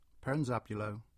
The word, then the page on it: Perranzabuloe: English